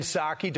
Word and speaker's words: Psaki: Chuck Schumer could be the next Democrat, the target of Bernie Sanders and Jen Psaki, don't pronounce the P, as they take aim at the future of their party, slamming the Senate minority's claim that the left is united.